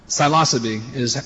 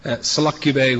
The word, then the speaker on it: Psilocybe: Psilocybe is how American mycologists pronounce it. Selucubae would probably be more truthful to the Greek. But it's an ancient word, and the original pronunciation is not well known. Psilocybe is preferred.